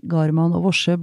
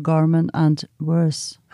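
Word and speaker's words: Garman & Worse: Og jeg, eh, jeg holdt på å besvime da jeg hørte at Alexander Kiellands klassiske verk 'Garman og Vorse' ble uttalt som 'Garman and Worse'.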